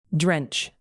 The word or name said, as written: drench